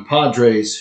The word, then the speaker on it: Podres: And so, just, I think it's just really cool that Johnny Padres, whose name is Podres, but it's pronounced Padres, ended his career with the San Diego Padres, Padres.